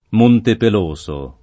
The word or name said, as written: Montepeloso